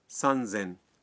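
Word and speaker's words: سان زین (三千): جی بالکل ٹھیک، لیکن یہاں بھی کچھ اعداد کا معاملہ مختلف ہے۔ 3000 کو کہتے ہیں سان زین، اور 8000 کا تلفظ ہے ہس سین۔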